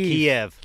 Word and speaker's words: Kyiv: The coast of Kyiv, how do you say this? – Coast of Kiev.